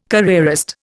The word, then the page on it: careerist: Indian